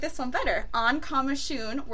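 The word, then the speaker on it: Coimisiún: The largest Irish step dancing competitions, I'm going to butcher this hardcore, on Comishoon? – Comishoon. Very easy. It only took you 3 attempts. – Shut the front door. Yeah. Oh, I'm so excited. Comishoon.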